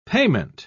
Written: payment

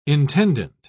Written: intendant

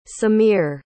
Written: samir

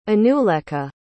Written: anulekha

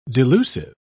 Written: delusive

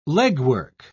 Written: legwork

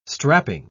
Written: strapping

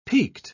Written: piqued

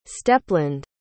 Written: steppeland

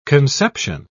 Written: conception

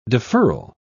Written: deferral

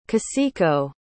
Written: kaseko

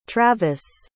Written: travis

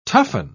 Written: toughen

